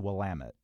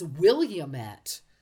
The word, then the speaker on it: Willamette: Of course, looks like Willamette, but I believe it's Willamette. – Yes, correct.